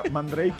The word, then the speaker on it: Mandrake: Que además ahí sí era Mandrake y nosotros Mandrake. – Mandrake, sí. Mandrake, no sabía que se pronunciaba eso.